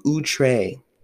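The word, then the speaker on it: outré: It's O-U-T-R-E.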